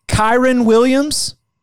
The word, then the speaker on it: Kyren Williams: And, you know, as I was looking at Brian Kelly's press conference from today, Kyren Williams, is that how you pronounce yeah.